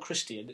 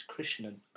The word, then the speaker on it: Krishnan: No, not Christian, it's Krishnan.